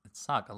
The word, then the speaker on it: Saga: It's Saga, like a saga.